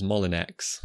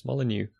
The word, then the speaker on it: Molyneux: A lot of Americans seem to think his name is Molinex, which sounds like a drug. But just to let you, anyone listening will know, because we say his name often enough, but it's not Molinex, it's Molyneux.